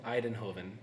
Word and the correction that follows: Eindhoven: No, it's Eindhoven. – Eindhoven. Okay. Yeah.